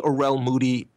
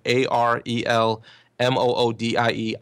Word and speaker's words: Arel Moodie: I'm the only Arel Moodie in the world. If you look in the show notes, you see how my name is spelled, Arel Moodie.